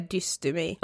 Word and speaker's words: dystymi: Ja vi visste typ inte hur vi skulle uttala det. Jo men det är dystemi. Ja dystemi.